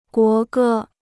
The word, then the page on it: 国歌: guó gē